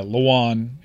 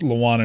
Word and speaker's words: Lewan: Yeah, Lewan. It looks like Lewin, right? But I believe I've heard it pronounced Lewan enough times to go with that.